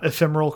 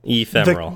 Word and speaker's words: ephemeral: Um, he says he thinks we pronounced uh, ephemeral correctly, and he so said the hard the hard e the hard e is the the correct way. – Now ephemeral.